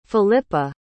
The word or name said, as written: filippa